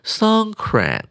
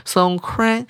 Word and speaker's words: Songkran: 我们来看下一句，Songkran 啊，我念的是泰式的方法，英文要念成 Songkran，这个重音有点不一样。 Songkran 有没有种泰文的感觉呢？